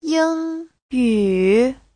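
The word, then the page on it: yingyu: ying1yu3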